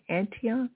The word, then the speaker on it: Antion: Antion? It's so cool. It's A-N-T-I-O-N.